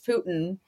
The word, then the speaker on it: Putin: Or, like, I want Putin, which, by the way, I remember last time we talked about Putin, I was like, Putin. Like, I was pronouncing it like a pew. – Putin. – And then everyone yelled at me. I'm like, okay, sorry, it's Putin.